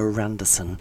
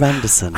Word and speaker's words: Anderson: They do put an R in strange places where they pronounce the name Pamela Randerson and they put an R in the middle where there's no R, but they can't pronounce the word car.